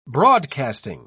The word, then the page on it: broadcasting: British English